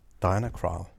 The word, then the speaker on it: Diana Krall: Diana Krall. Og det udtales sådan, det siger hun selv.